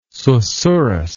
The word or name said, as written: susurrous